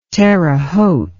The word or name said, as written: Terre Haute